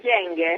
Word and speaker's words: Kyenge: Intanto ci tolga il dubbio, la pronuncia è corretta, Kyenge, perché l'abbiamo sentita pronunciare in tanti modi. – Sì, è corretto, Kyenge.